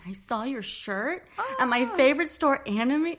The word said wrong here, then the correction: Anomie: We get like a lot of Anomi, Anami, blah, blah, blah. But it's Anomi? – Anomi. Yeah. Okay.